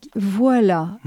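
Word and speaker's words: Voix-là: Voilà. Mmh. Et Voilà s'écrit V-O-I-X, plus loin, là.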